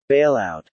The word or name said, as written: bailout